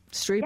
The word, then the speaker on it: Striepe: Streepy.